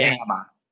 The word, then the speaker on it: IAMA: Is I say that right? Is that right? – IAMA.